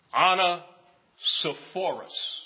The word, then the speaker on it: Onesiphorus: And again, his name, I believe, is properly pronounced Onesiphorus.